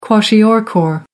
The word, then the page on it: kwashiorkor: American English